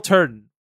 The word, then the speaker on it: Turton: But yeah, it's Will Turton not Thornton, Turton, T-U-R-T-O-N. I apologize for mispronouncing your name.